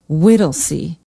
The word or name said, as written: whittlesey